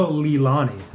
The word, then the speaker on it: Leilani: Alaliani. It's L-E-I-L-A-N-I.